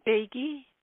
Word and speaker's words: Beigi: How do I say your last name? – Beggy. – Beggy. – Yes.